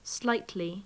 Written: slightly

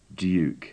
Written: duke

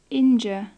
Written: injure

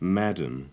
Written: madam

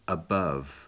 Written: above

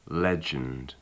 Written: legend

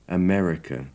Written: america

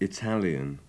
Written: italian

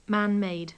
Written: man-made

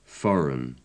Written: foreign

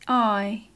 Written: eye

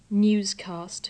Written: newscast

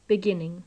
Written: beginning

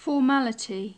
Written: formality

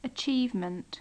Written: achievement

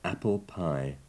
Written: apple pie

